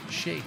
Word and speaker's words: Sheik: Shake or chic? Uh, I believe it is pronounced shake.